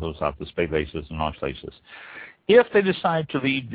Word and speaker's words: Auslese: and this is referred to as an Auslese. That's spelled A-U-S-L-E-S-E, an Auslese.